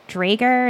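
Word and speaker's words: draugr: is it Drager, Is that how you say it? – Right. Yeah.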